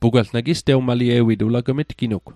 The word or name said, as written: tewmalie'wit